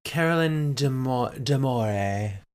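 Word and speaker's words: Caroline D'Amore: Do you know how to say this? – Carolyn Damore.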